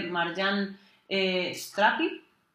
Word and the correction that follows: Satrapi: o Satrapi, perdón,